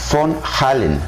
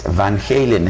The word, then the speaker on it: Van Halen: En realidad la pronunciación en holandés es Von Halen, Von Halen, eh, pero como el grupo se dio a conocer allá en los Estados Unidos, en California, la pronunciación internacional con la que conocemos a Eddie Von Halen y a su grupo es Van Halen, es la que estoy utilizando, pero si quieres Guadalupe, tú puedes pronunciarlo Von Halen.